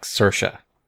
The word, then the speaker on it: Saoirse: I think it's closer to like Saoirse.